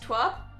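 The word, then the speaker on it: TWRP: Starlight Brigade by, I think... it's pronounced Twerp.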